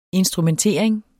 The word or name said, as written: instrumentering